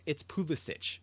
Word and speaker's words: Pulisic: It's Pulisic. That's how his grandpa pronounced it. It's how he should pronounce it.